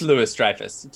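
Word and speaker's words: Louis-Dreyfus: So we had Val come in and get John Walker as it is Louis Dreyfus. Julia Louis Dreyfus. – It is. Okay. Yeah. God. So you've been saying it wrong this whole time.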